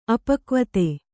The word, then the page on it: ಅಪಕ್ವತೆ: apakvate